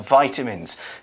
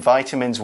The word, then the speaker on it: vitamin: The word is spelled V-I-T-A-M-I-N, vitamin. In the UK, we say vitamin. – Vitamin. – So don't worry if you sometimes hear it different. Vitamin, vitamin, it's the same.